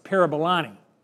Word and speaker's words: Parabolani: Let me spell that for you. P-A-R-A-B-A-L-A-N-I. Sounds like Parabolani. It's Parabolani. B-A-L-A-N-I.